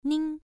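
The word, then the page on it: ning: tone 1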